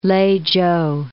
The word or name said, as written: Lei-chou